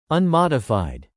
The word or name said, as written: unmodified